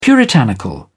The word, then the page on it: puritanical: British English